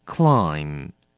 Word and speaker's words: climb: 第二个字 climb. 动词，爬，攀登。要小心了，这个字也是 MB, 放在字尾，我们只发 M 的音，念成 climb。